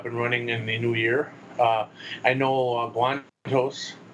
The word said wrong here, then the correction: Guanatos: Apparently, I've been saying it wrong. It's Guanatos.